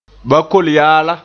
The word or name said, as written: bakol-yaala